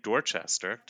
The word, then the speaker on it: Dorchester: So this email is from Jeff in Dorchester. Did I pronounce that right, Chris? – Yes, that would be correct.